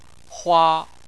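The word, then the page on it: hua: tone 1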